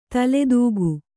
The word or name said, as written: ತಲೆದೂಕ